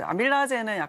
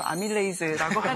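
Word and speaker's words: amylase: 네, 이제 아밀라제는 약간 좀 구식 발음이고 아밀레이즈라고 하는데.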